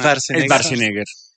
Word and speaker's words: Schwarzenegger: ¿Es Schwarzenegger? – Para nosotros nos enseñaron. – Es Schwarzenegger.